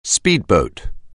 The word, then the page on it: speedboat: anglais